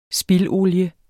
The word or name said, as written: spildolie